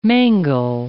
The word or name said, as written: mangle